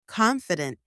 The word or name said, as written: confident